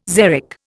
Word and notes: xeric: Indian

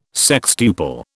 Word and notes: sextuple: British English

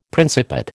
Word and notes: Principate: American English

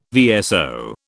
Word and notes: VSO: British English

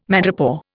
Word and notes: madrepore: Indian

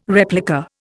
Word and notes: replica: Indian

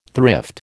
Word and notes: thrift: American English

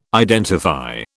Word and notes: identify: British English

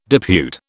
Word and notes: depute: British English

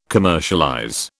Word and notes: commercialize: British English

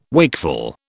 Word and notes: wakeful: British English

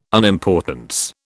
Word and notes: unimportance: British English

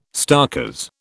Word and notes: starkers: British English

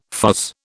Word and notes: fuzz: British English

